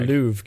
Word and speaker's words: Louvre: The museum is called the Louvre, Kevin.